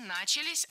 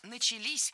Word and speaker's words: начались: И, конечно же, в сентябре 42-го года уже начались попытки именно создания такого фронтового снайперского движения. – Не начались, а начались. Ударение ставим опять же на последний слог, в самый конец слова.